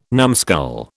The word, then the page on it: numbskull: British English